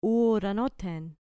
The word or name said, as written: uranothen